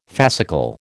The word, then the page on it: fascicle: American English